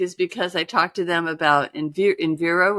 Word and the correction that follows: Envigo: Was that how you pronounce it? Which, Invigo. – oh, Envigo. Yeah.